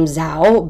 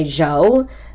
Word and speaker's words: Zhou: Zhou Guanyu, which I would have pronounced his name Zhao, but they all say Zhou, Z-H-O-U.